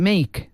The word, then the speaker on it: Make: Si no lo he pronunciado bien Mike. En inglés, te lo escribo. – Mike. – ¿Vale? – El nuevo. El no es. Es Make. Make. El resto bien.